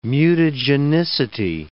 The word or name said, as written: mutagenicity